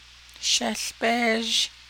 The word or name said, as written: shéłbéézh